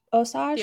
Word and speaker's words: Osage: Am I saying this right? Osage? – The Osage? Mm-hmm.